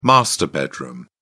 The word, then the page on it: master bedroom: British English